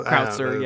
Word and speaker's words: Krauser: I don't think the fight between Leon and Krauser, that's how you pronounce his name, right? Krauser? – Krautzer, yeah.